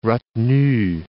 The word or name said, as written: retenue